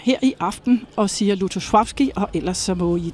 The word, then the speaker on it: Lutosławski: Altså de fleste her på bagscenen siger faktisk Lutosławski, har jeg lagt mærke til. Men altså når jeg er i selskab med polakker, så siger jeg det i hvert fald ikke. Fordi der hedder det Lutosławski. – Der er den der lille streg over det andet L, som betyder, at man skal udtale det mere som V.